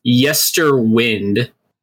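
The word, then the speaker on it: Yesterwynde: I try to pronounce this, Yesterwind. – That sounds right.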